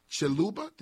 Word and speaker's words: Chiluba: So the name and the mission of Akula comes from Chiluba. Did I say that right? – Yeah.